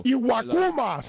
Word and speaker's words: Iwakuma: Bueno, primero pronúncialo bien. – Ok. Ok. Ok, el ganador fue Aukama, el japonés. – Ay, Dios mío. – Tiene 13-6 y tiene 2.76 de efectividad, quinto. – Iwakuma se llama el japonés.